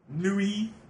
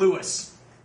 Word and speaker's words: Louis: Louis... There's a fucking S in that. Say goddamn Lewis.